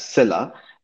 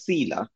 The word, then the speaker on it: Sila: Well, so I'll say that I pronounce it as Silla, but it also has the meaning of, I think, like force or strength in Russian and other, you know, Eastern European languages. And there it's pronounced as Sila. But I've always pronounced it Silla, and I think that's probably the official one.